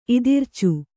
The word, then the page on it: ಇದಿರ್ಚು: kannada